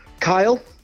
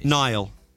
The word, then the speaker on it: Niall: His name rhymes with while. – Kyle? Is it Kyle? – Niall.